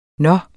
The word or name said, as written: nå